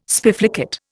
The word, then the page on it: spiflicate: Indian